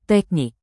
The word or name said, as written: teknik